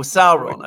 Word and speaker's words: Sauron: I wonder who Sauron is, or Sauron, I believe the correct pronunciation.